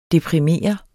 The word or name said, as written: DEPRIMERE